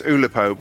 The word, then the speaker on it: Oolipo: It's not spelt as you might imagine, as it's spelt O-O OOLIPO. So it's Oulipo, but with two O's rather than O-U. So OOLIPO.